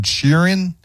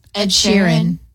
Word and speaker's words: Sheeran: Ed Sheeran? – Ed Sheeran.